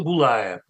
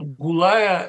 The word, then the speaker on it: Гулая: Вот судьба Ирины Гулая, понимаете? Это же, некоторые произносят Гулая, ну, конечно, Гулая.